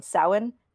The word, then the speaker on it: Samhain: Like today in Wiccan holidays or pagan holidays would be called Samhain, but it's spelled S-A-M-H-A-I-N, and they pronounced it Samhain on the show.